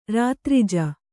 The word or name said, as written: ರಾತ್ರಿಜ